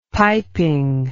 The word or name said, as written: piping